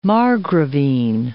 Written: margravine

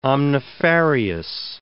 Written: omnifarious

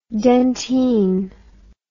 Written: dentyne

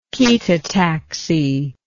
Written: chaetotaxy

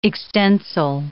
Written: extensile

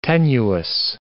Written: tenuous

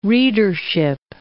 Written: readership

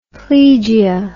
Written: plegia

